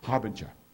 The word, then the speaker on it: Harbinger: Harbinger. I wasn't saying it quite right. I knew it wasn't. Harbinger.